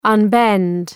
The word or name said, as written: unbend